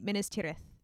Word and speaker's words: Minas Tirith: Wait, Minas Tirith. That's how you say it. I had to get the accent.